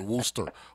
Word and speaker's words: Worcester: Like Worcester is pronounced Wooster.